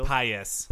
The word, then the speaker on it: Paes: Is Mayor Eduardo Paes, P-A-E-S? How would you pronounce that? – Paes. – Paes. Eduardo Paes.